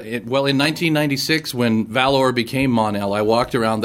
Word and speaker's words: Mon-El: Mon-El, and you have to say it right, because Mon-El, much like Scott Shaw, has to be said as though there's an exclamation point at the end.